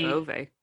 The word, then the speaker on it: Beauvais: what's the other one? Beauvau? Am I saying that right? – Beauvais.